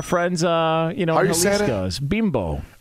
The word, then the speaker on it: Bimbo: Story, now it's bimbo.